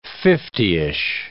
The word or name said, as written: fiftyish